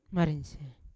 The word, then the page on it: marisian: Yawa